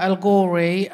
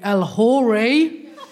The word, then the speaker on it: El-Ghorri: So, as Deborah said, my name is Fatiha El-Ghorri, but my surname's got a H in it. It's a silent H, but a lot of people pronounce it, so they always call me El-Ghorri.